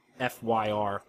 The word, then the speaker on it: Fyr: The 3,000-year-old, later 4,000-year-old Telvanni wizard who appears in FYR. I always pronounce it weird. It's like fur.